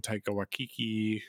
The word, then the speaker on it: Taika Waititi: Taika Waititi, if I pronounce his name wrong, I'm sorry. – Apparently not. – It's just fun to say Taika Waititi.